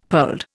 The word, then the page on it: pearled: Indian